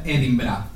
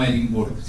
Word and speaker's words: Edinburgh: I el tercer, que la ciutat es pronuncia Edinburgh, no Edimburg.